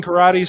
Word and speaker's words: Corradi: Yes, but it's spelled C-O-R-R-A-D-I, but it is pronounced karate.